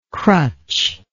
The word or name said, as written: crutch